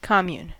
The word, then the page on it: commune: American English